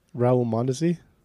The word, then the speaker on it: Raul Mondesi: How do you pronounce the name? – Raul Mondesi.